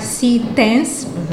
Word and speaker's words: Citens: Nós estamos a pronunciar Citens, porque o C é da forma como se pronuncia em inglês.